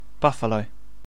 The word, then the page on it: buffalo: British English